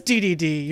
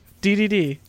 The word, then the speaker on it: Dedede: Oh, it is DDD. You're right. DDD. – DDD? I forgot that it was 3Ds. But, DDD. – But, yeah, I mean, the boss fight with DDD is cool. DDD.